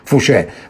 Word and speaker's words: Fouché: L'altro rappresentante che arriva a Lione dopo che Lione è caduta è Fouché, che si scrive F come Firenze, O come Otranto, U come Uganda, C come Cicciolina, H come Help, E con l'accento francese. Fouché.